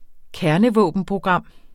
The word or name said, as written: kernevåbenprogram